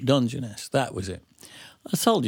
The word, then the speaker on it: Dungeness: It's got a U in it and three syllables. Dungeness.